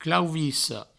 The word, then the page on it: clauvissa: clauvisso